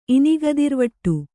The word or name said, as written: ಇನಿಗದಿರ್ವಟ್ಟು